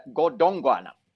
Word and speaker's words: Godongwana: So, Mr. Godwana, Godongwana, I must pronounce it correctly.